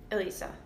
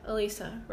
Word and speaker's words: Elissa: I think it's Alyssa. – How do you spell it? – E-L-I-S-S-A. – Alyssa. – Alyssa, right? – Yeah.